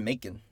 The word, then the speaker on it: Macon: Yeah, so they're doing the letters, that's a good character moment, and then that's when we end up in Macon, Georgia? – Macon. I always pronounce it Macon. – Macon? – Like bacon.